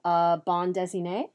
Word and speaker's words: bande dessinée: in the original bande dessinée, did I say it right that time? – You did.